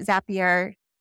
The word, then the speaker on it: Zapier: So what Zapier, hopefully I'm saying it correctly.